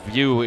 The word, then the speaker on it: Vieaux: And View is spelled the Cajun way, V-I-E-A-U-X.